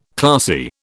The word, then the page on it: classy: British English